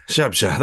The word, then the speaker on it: Zabrze: Ruch Chorchow versus Górnik Zabrze. – Szabsza, there you go.